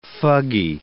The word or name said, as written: fuggy